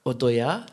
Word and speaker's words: Odoya: Now, your other project, now, excuse me if I don't pronounce it properly. – Give it a try. – Odoya. – Perfect! – Yeah. Wow! – That's rare! – Hey! – Amazing! Even the Latinos sometimes pronounce it wrong.